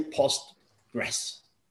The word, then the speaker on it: Postgres: Now I understand that sometimes PostgreSQL it's a bit hard to pronounce. So it's also accepted if you just say Postgres.